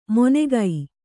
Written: ಮೊನೆಗೈ

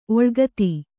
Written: ಓಲೆಗಾತಿ

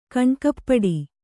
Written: ಕಣ್ಣುಕಪ್ಪಡಿ

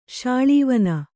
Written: ಶಾಳೀವನ

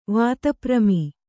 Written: ವಾತಪ್ರಮಿ

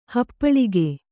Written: ಹಪ್ಪಳಿಕೆ

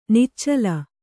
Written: ನಿಚ್ಚಲು

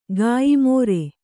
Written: ಕಯ್ಮರೆ

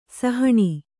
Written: ಸಹನಾ

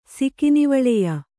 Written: ಸಿಕಿನಿವಳೆಯ

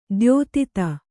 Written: ದ್ಯೋತಿತ

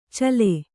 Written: ಛಲ

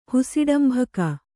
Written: ಹುಸಿಡಂಭಕ

